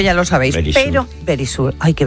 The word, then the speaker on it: Verisure: Ahora hablo, lo que ocurre es que Securitas Direct ahora se llama Verisure y vosotros no podéis estar así tan tranquilos y deberíais recordarlo.